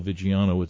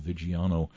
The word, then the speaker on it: Viggiano: Yeah, just Paul Viggiano, it's V as in Victor, I-G-G-I-A-N-O, Viggiano.